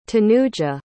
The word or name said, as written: tanuja